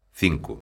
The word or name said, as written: cinco